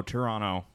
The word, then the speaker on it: Toronto: T-U-R-R-U-N-N-O. Toronto.